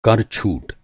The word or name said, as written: छूट